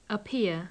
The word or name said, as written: appear